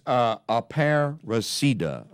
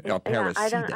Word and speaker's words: Aparecida: It's Aparecida, I'm being informed. I'm mispronouncing the name. Aparecida. – I wasn't sure if you made a hard C either.